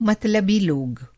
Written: मतलबी

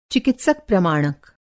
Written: चिकित्सक प्रमाणक

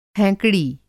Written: हैंकड़ी

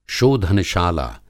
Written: शोधनशाला

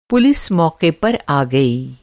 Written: मौक़ा

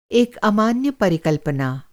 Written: परिकल्पना

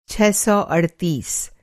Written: छह सौ अड़तीस

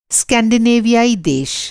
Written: स्कैंडिनेवियाई

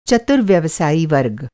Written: व्यवसायी

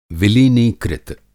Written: विलीनीकृत

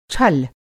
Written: छल

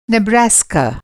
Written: नेब्रास्का

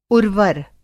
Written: उर्वर

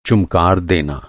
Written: चुमकार

